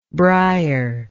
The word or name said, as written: briery